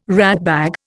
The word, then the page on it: ratbag: Indian